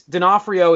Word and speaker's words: D'Onofrio: All I know is D'Onofrio is how I say it for – I have people at my – where I work that are called – that's their last name, and that's how they pronounce it.